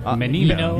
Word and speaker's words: Menino: Menino.